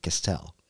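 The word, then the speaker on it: Castel: That's how you pronounce it, Castell.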